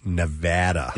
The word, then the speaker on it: Nevada: They hate it when you call it Nevada. It's Nevada. – Yeah. – That's how the locals pronounce it, Nevada.